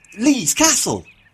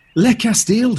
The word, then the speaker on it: Le Castel: Lee's Castle. – Lee's Castle? What kind of French pronunciation is that? Le Castel.